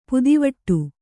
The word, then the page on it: ಪದವಿಡಿ: padaviḍi